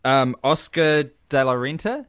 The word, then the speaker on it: Oscar de la Renta: Oscar De La Renta? Is that how you say it? – Yes, nailed it.